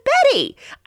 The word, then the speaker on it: Betty: Do you spell it with a C or a K? And I'm like, it's a B. She's like, what? I'm like, my name is Betty.